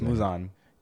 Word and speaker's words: Muzan: Yeah, say it right. It's Muzan.